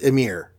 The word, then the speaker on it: Ymir: I won't go into the names that are missing, since I don't want to get you guys tongue-tied, except to say that in Old Norse, Y's are pronounced like I's, so the giant's name is pronounced Ymir.